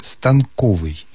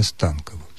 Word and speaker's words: станковый: Сергей Юрьевич, словари русского языка рекомендуют произносить это слово только с ударением на втором слоге «станковый». И более того, в словаре ударений под редакцией Резниченко делается дополнительное замечание. Неправильным является ударение «станковый».